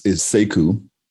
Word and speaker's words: Seku: So my name actually is Seku, and it's how you pronounce it, and it means warrior, and it also means learned.